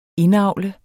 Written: indavle

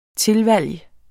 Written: tilvalg

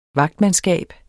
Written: vagtmandskab

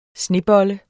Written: SNEBOLLE

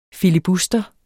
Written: filibuster

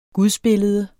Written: gudsbillede

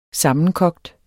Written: sammenkogt

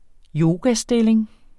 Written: yogastilling

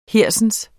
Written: hersens

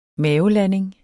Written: mavelanding

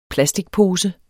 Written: plasticpose

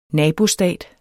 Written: nabostat

